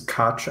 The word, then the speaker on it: Koch: So my last name is Koch. I tell people to pretend you're British and say the word catch, Koch.